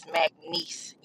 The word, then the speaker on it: McNeese: I would say I am listening to the Monique Kitts episode right now, and I love y'all, but y'all are butchering these names for Louisiana. It is Plaquemine, Louisiana, and it is McNeese University.